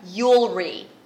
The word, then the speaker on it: Yulri: Her name, which by the way is beautiful, Yulri. And she said it rhymes with jewelry.